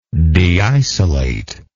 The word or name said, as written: deisolate